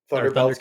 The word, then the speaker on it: Thundercats: And I had a Thundercats belt buckle. Whew. I think I still have it. Man, the drip. Let me tell you, I always felt so swaggy with that Thunderbelts belt buckle on. – Thundercats cat buckle.